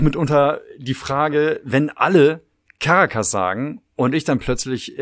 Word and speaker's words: Caracas: Caracas, genau. Ich dachte immer, die Hauptstadt von, oh Gott, Venezuela, ne? Ja, ist äh, Caracas, nicht Caracas, genau. Aber richtig ausgesprochen ist es offenbar Caracas.